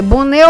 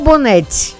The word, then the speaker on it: Bonet: Escreve B-O-N-E-T. Pode ser Bonnet ou Bonnette, né? Alguns têm uma diferença de pronúncia.